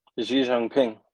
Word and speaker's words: Xi Jinping: Xi Jinping.